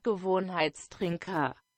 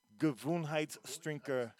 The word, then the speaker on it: Gewohnheitstrinker: But in German, it is pronounced Gewohnheitstrinker. Gewohnheitstrinker. – Gewohnheitstrinker. I don't think I said anything close to that.